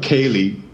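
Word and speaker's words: céilí: So if you look up Kaylee music, you'll get a ton of very fast. High tempo traditional Irish music, and phonetically it's pronounced some other way, but it's exactly the same as Kaylee.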